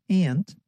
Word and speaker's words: aunt: ส่วนคนอเมริกันทางฝั่งตะวันออกเฉียงเหนือของประเทศนะคะจะออกเสียงคำว่าป้าว่า aunt ค่ะฟังตัวอย่างนะคะ People say the word aunt like aunt. Listen.